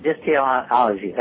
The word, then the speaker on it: Dysteleology: Dysteleology? Is that, am I pronouncing that right? – Dysteleology.